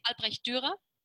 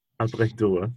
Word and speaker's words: Albrecht Dürer: And the famous son is a guy called Albrecht Dürer. Have I pronounced that correctly, first of all? – Yes, yes, you do. In German, we say Albrecht Dürer. – Albrecht Dürer. – Yeah, but you're right, Dürer.